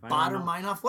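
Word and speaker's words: Baader-Meinhof: This is how did you say to pronounce this band? Bador Meinhof. – It's not German. Speech, Baden Meinhof. Bader Meinhof. – Bader Meinhof.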